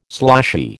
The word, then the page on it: sloshy: American English